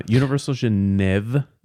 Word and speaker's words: Universal Genève: Universal Genève.